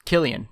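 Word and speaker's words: Kilian: And then the ace of the staff, Caleb Killen. I believe that's how you pronounce his name. He's 13 starts. Killian, thank you. That sounds better.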